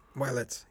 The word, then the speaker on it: Widelitz: And in terms of the score, you had Stacy, is his last name pronounced Widelitz? – Willits, yes.